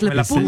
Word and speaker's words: La Punt: Et moi, j'ai appris comme parole, je ne savais pas, qu'on dit, on dit pas le pont, on dit la, donc c'est féminin, pun, alors qu'il y a un T à la fin, mais on ne le prononce pas, la pun.